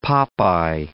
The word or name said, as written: pop eye